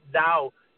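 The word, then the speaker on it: Dao: That gap, yeah, that, you see, the Tao, you know, the Tao, T as in Tom, A-O, or they pronounce it Tao, D as in David, A-O.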